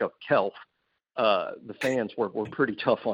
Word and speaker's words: Kecalf: And now I'm going to tell you it's spelled K-E-C-A-L-F. And it's pronounced Kelf.